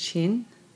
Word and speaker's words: Qin: So my name is Chin, spells Q-I-N, but pronounced C-H-I-N.